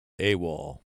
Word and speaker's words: AWOL: In that war, they pronounced the initials AWOL. Come World War II, the initials of the acronym become a word. AWOL.